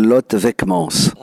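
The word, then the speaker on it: Lot Vekemans: Écoutez, Annick, avec tout le respect que je vous dois, vous avez moyennement prononcé au sens où elle s'appelle Lot Vekmans.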